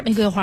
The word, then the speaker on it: 玫瑰: 哎，玫瑰花，玫瑰花啊，标准读音玫瑰，第二声是清音啊、嗯、但是在今天这个不能这么念、哎、这个要念玫瑰花。